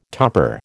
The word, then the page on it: topper: American English